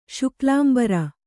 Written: ಶುಕ್ಲಾಂಬರ

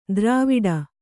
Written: ದ್ರವಿಡ

